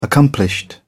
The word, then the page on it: accomplished: British English